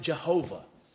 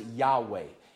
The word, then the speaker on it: Jehovah: And so we pronounce it Jehovah. Some have tried to pronounce it Yahweh. At best, that's a complete guess that kind of runs amok of the Hebrew language.